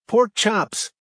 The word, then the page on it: pork chops: American English